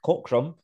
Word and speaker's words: Cockram: I'm with a friend of mine today, Mark Cockram, from All the Space and Time podcast.